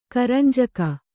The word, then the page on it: ಕರಂಜಿಗೆ: karanjige